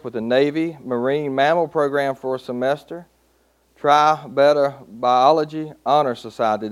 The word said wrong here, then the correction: Tri Beta: Tri Beta.